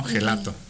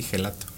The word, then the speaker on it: gelato: Porque se escribe con G, ¿no? Gelato. De hecho, hay quien lo pronuncia así, gelato. La forma correcta es gelato, pero eso era de nuestros principales retos.